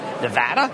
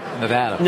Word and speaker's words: Nevada: You were telling me that I pronounced Nevada wrong. Nevada? – Nevada.